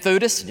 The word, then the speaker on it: Theudas: One named Theudas, just happened to rhyme with the man named Judas of Galilee, rose up in the days of the census.